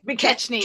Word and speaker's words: McKechnie: McKechnie, but that's okay. – McKechnie.